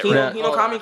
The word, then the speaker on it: Hinokami: Hinokami Chronicles.